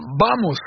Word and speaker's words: Vamos: Vamos, ça se prononce comme ça. Le V se prononce B en espagnol, je ne parle pas espagnol.